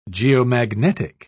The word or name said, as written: geomagnetic